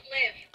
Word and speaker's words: LIV: It's live. – I believe it's pronounced live, though.